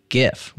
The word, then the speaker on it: GIF: It boasts better image compression than WebP, JPEG, PNG, and GIF with a hard G.